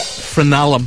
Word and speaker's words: frenulum: So number five, which is the stringy thing on your tongue, is actually known as the frenulum, ladies and gentlemen. – How do you say it? – Frenulum.